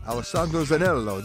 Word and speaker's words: Alessandro Zanello: Honored to have with us the captain of the Silver Spirit, Alessandro Zanello.